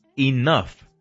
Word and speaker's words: enough: Nuestra palabra de hoy es buena porque no se pronuncia como muchos pensaríamos al verla escrita. Se dice enough. – Enough. – Enough. – Enough. – ¿Eh? Y enough. En español, si lo llegas a escribir, es la letra I-N-O-F.